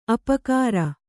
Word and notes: ಅಪಕಾರ: apakāra

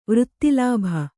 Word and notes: ವೃತ್ತಿಲಾಭ: vřtti lābha

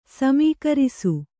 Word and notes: ಸಮೀಕರಿಸು: samīkarisu

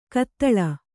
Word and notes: ಕತ್ತಳ: kattaḷa

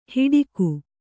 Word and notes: ಹಿಡಿಕು: kannada; hiḍiku

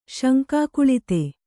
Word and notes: ಶಂಕಾಕುಳಿತೆ: Kannada

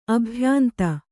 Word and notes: ಅಭ್ಯಾಂತ: abhyānta

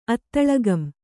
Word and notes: ಅತ್ತಳಗಂ: Kannada

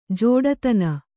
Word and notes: ಜಡತನ: jaḍatana

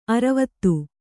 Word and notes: ಅರ್ವತಿ: Kannada

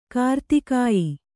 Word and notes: ಕಾರ್ತಿಕೇಯ: kartikēya

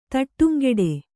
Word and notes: ತಡಂಗೂಡು: Kannada

